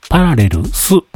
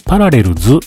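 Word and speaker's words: Parallels: パラレルスと言います。なんかね、パラレルズっていう風に発音される方もいらっしゃるんですけれども、パラレルスっていうアプリだと聞いております。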